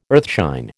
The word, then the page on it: earthshine: American English